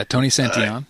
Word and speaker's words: Santillan: Santillan, I think is how you pronounce his name, the pitcher. – Yeah, Tony Santillan.